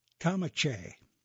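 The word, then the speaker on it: kamancheh: It's pronounced as a kamanche.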